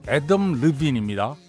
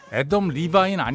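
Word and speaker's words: Adam Levine: 그리고 이 밴드의 리드 보컬리스트의 이름은 애덤 르빈입니다. 애덤 리바인 아니거든요. 애덤 르빈. 확실하게.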